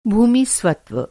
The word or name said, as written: स्वत्व